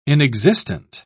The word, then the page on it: inexistent: British English